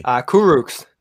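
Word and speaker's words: Kurucs: Exum, pronounce it for me. – Kuroks. – Kuroks, yeah. Right? Kuroks. – Kuroks. Exum, bro. You said it right.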